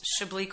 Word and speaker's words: Chablis: So I'd love it if you could like my Facebook page, which is Chablis Quarterman.